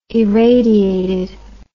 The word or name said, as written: irradiated